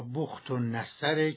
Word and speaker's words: بخت النصر: بله، صحیحش بنو کد نصر یا بخت النصر که جناب عالی هم صحیح تلفظ فرموده.